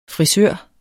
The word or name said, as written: frisør